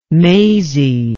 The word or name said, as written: Maisie